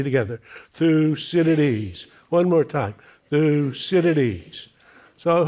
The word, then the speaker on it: Thucydides: So if nothing else today, you can tweet your friends, I met a great thinker, and I can even pronounce his name, Thucydides.